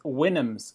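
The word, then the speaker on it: Houyhnhnms: It's Winnems. So it's supposed to represent like a whinny, which is the sound. Oh. Like alongside, and there's two sounds, I suppose, that horses make, a whinny and a neigh.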